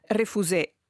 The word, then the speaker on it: Refusé: dove vive e dove ha cofondato Refusé. È giusto pronunciarlo alla francese, tra l'altro? – Corretto, viene proprio dal Salon des Refusés.